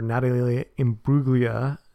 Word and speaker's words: Imbruglia: It's actually a shot-for-shot remake of the video for Natalie Imbruglia.